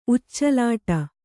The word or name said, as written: ಉಚ್ಚಲಾಟ